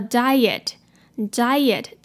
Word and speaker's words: diet: 好，这就是今天的句子。接下来我们来看今天的 pronunciation tips。首先，a diet。Diet